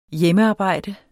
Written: hjemmearbejde

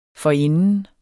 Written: forinden

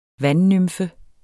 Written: vandnymfe

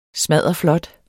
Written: smadderflot

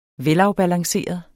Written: velafbalanceret

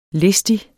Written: listig

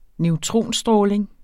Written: neutronstråling

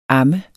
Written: ammes

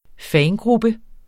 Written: fangruppe

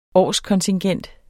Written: årskontingent

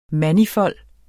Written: manifold

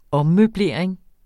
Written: ommøblering

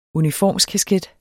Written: UNIFORMSKASKET

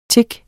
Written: tik

